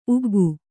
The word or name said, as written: ಉಗ್ಗಿ